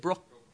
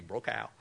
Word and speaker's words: Brokaw: Would you pronounce it? Brokaw. Thank you, Brokaw.